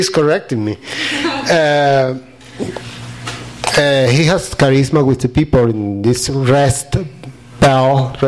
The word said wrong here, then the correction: Rust Belt: I pronounce that well? – Rust Belt. – The Rust Belt.